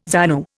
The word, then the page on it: ZANU: Indian